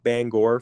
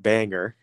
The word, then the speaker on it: Bangor: Not Bangor for all of you Maine listeners, but Bangor, spelled the same, messed me up on my mission.